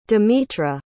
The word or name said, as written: demetra